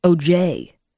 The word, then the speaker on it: O.J.: Or as Howie always says, O.J. He puts the emphasis on the J. I've never understood that.